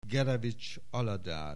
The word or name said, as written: Gerevich Aladár